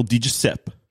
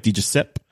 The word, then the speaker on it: DiGiuseppe: So I was out in Abbotsford chatting with Phil DiGiuseppe. – Nice. – That's how you say it, right? DiGiuseppe. – Well, you could throw the E on there.